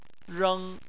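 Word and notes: reng: tone 1

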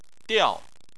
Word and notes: diao: 4

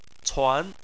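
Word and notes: cuan: tone 2

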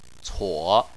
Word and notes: cuo: 3

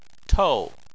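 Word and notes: tou: tone 4

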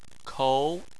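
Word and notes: kou: tone 2

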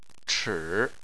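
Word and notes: chi: tone 3

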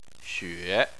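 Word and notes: xue: tone 3

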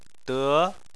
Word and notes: de: 2

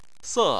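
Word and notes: se: tone 4

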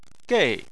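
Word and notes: gei: tone 4

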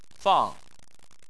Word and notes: fang: 4

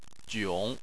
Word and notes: jiong: 3

